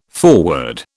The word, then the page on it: foreword: British English